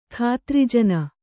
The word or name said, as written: ಖಾತ್ರಿಜನ